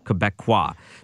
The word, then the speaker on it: Québécois: How do you say it? Bloc Québécois. – Is it just Québécois.